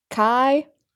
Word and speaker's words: Kyela: Kyela.